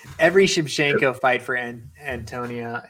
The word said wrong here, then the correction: Antonina: is dude, God, I know I butchered it again. Antonina.